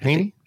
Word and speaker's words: Heaney: But you wrote about it a little bit in the context of, it's Seamus Heaney. Is that how you say it? – Haney.